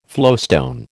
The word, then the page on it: flowstone: American English